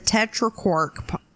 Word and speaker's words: tetraquark: Well, next we have physicists discover the tetraquark particle.